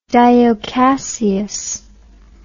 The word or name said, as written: dio cassius